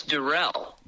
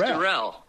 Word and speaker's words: Duriel: It's Duriel. – Duriel. – It's Duriel. You were trying to make it a little French. It's just Duriel.